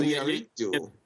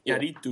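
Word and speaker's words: Iñárritu: Well, and his last name's hard because you got that like little like tilde over the end, so you have to roll your tongue. So Inyaritu. – Sound in there. Inyaritu. Yeah. – And then you have to put the emphasis on the A right after it.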